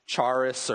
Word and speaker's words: Charis: It's C-H-A-R-I-S. And people will call their daughter, I don't remember how they say it, like Charis or Charisse or something like that. Well, the C is actually silent. So if you name your kid that, sorry, but it's Charis.